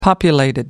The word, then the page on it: populated: British English